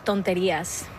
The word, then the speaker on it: Tonterías: Tonterías. – Tonterías.